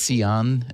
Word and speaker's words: Sian: And do you want to just explain why your name is Sion and not Sean or something? It's spelled S-I-A-N.